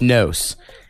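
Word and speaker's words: KNOES: It's spelled K N O E S. K N O E S, pronounced Nose.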